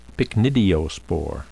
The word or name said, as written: pycnidiospore